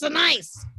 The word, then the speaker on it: Sinise: Maybe we are not even saying it right. You know, okay? Let's be real. He's really like, you fuckers, it's Sinise. Anyway.